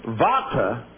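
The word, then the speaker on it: bhata: Pronounced vata, not bata.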